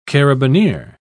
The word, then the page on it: carabinier: British English